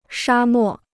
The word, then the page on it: 沙漠: shā mò